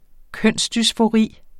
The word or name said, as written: kønsdysfori